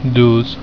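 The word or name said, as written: douze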